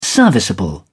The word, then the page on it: serviceable: British English